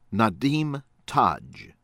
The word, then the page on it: AHMED, QAZI HUSSEIN: kah- ZEE hoo- SAYN ahh- MEHD